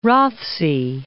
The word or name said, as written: Rothesay